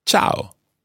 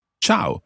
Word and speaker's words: ciao: In Italian, you say ciao. – Ciao. – Ciao. Now, be careful with the pronunciation of this word. Quite often people think it's ciao, almost as if it's spelled C-H-O-W, but it's not.